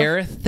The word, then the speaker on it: Aerith: I don't want to butcher it on Aerith. – Camera. Yep. Aerith.